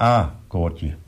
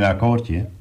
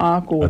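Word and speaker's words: A-akkoordje: Het A-koordje, snap je? Het was geen akkoordje. – Oh, A-koordje. – Het was een A-koordje. – Ja, nou, dan zei ik het fout. Nou, hoe grappig. – De klemtoon lag verkeerd.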